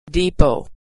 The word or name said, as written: depot